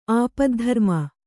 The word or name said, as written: ಆಪದ್ಧರ್ಮ